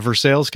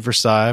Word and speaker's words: Versailles: A listener local here from Versailles, Kentucky, spelled just like Versailles, but we don't pronounce it that way in Kentucky.